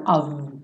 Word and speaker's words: of: Todo of vai ser pronunciado of com V, né? Esse OF vai ser of toda vez, sem exceção, ok?